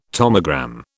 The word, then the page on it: tomogram: British English